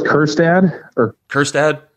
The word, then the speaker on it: Kjerstad: Heston Kjerstad out of Arkansas. Is that right? – I go with Kjerstad. Like Erstad, but with a Kerr in front of it.